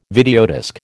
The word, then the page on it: videodisc: American English